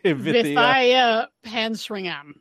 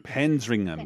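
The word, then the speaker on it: Vithaya Pansringarm: and a name that is not phonetically spelled out. – Vithia Pansringham. – Pansringham.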